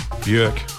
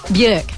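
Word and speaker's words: Björk: Bjork. – Bjork, there you go.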